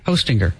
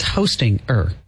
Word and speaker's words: Hostinger: H-O, like Santa, ho, and then stinger. – Hostinger. – Like a bee has a stinger. – But it's Hostinger. – Yeah, that's just how you spell it. – But it's like hosting, then you put an ER at the end.